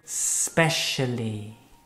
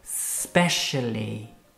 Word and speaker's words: specially: Specially. Yes, repeat. Specially. Specially. Let's hear you, Isabel. – Specially. – Yes, that's without the E, okay? Pues cuando nos referimos al propósito específico o la función de algo, okay?